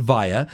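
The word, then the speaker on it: Viya: So we pronounce it VIA.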